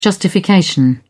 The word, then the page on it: justification: British English